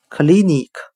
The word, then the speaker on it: clinic: 我们可以用谐音法来记忆这个单词 clinic，它的发音很像汉语的可立你咳，可以的可，立即的立，你自己的你，咳嗽的咳。